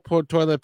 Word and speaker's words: toile: And it is spelled T O I L E. So people will say toil, it's but it's a French word, toile.